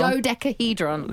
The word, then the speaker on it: dodecahedron: like decadahedron. I think I've said that wrong. Decahedron? – Dodecahedron? – Dodecahedron.